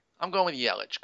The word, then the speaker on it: Yelich: How do you say it? I say Yelich. – Uh, well, I'm German, so I'd say Yelich.